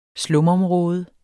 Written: slumområde

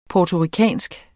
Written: puertoricansk

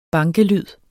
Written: bankelyd

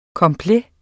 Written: complet

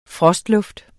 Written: frostluft

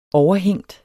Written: overhængt

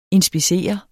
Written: inspektion

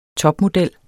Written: topmodel

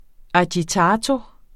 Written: agitato

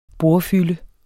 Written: bordfylde

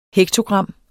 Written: hektogram

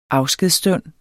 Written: afskedsstund

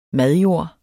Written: madjord

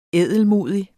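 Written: ædelmodig

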